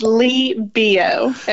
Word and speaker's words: Leigh Billeaud: It's Leigh Billeaud. Everything's silent. It's very French.